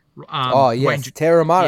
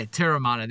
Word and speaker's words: Teremana: I definitely ran with a bit of Tramana, if I'm saying that correctly, tequila. – Teremana. Yeah. That's the rocks tequila. – Yeah. Teremana. There you go.